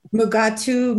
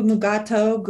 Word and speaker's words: Mugato: Mugatu, Mugato, Gumatos. – Yeah, evidently all are accepted pronunciations. – Yeah, so technically when this episode, okay, again, I'm just going to read this from Alex Perry over at TrekCore, but the episode that first introduced the Mugatu, which was a private little war, the animal was called the Gumato, but DeForest Kelly kept mispronouncing it and calling it Mugato. So the rest of the show dialogue was changed to match. So